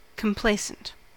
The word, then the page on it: complacent: American English